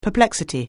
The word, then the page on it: perplexity: anglais